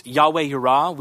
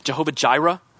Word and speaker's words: Yahweh Yireh: In Hebrew, this is two words. It's Yahweh Yireh. We kind of butcher that in English, and we call it Jehovah Jireh.